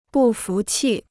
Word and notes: 不服气: bù fú qì

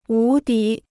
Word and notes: 无敌: wú dí

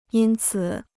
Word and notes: 因此: yīn cǐ

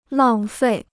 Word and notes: 浪费: làng fèi

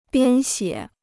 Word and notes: 编写: biān xiě

